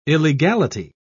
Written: illegality

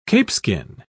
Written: capeskin

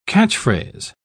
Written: catchphrase

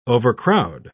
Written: overcrowd

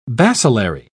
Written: bacillary

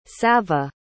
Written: savva

